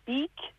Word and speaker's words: Bick: So tell us about the art exhibition, Willem Bick, if I got the pronunciation right. – Yeah, you've got it right, and that's it. It's Bick.